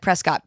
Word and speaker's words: Prescott: And I always hear it as Prescott because the town in Arizona is, it looks like Prescott, but everyone calls it Prescott. – Oh, is that how you say it? Prescott? Prescott. I did not know that. – In Arizona, you go to Prescott, not Prescott, but Prescott.